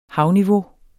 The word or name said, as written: havniveau